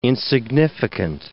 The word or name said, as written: insignificant